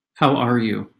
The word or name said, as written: how are you